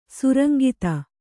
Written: ಸುರಂಗಿತ

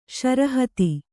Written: ಶರಹತಿ

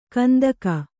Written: ಕಂತೆಗ

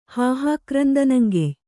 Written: ಹಾಹಾಕ್ರಂದನಂಗೆಯ್

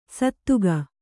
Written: ಸದೆಗ

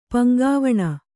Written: ಪಂಗಾವಣ

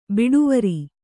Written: ಬಡಿವಾರ

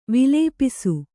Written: ವಿಲೇಪಿಸು

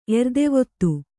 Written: ಎರ್ದೆವತ್ತು

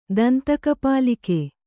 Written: ದಂತಕಪಾಲಿಕೆ

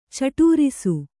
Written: ಚಟೂರಿಸು

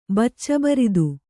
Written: ಬಚ್ಚಬರಿದು

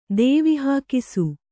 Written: ದೇವಿಹಾಕಿಸು

